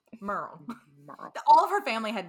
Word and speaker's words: Muriel: The pronunciation, it is spelled M-U-R-I-E-L. But when he started interviewing people, he realized that they more pronounce it like Merle.